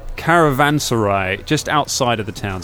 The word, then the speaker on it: Caravanserai: Caravanserai.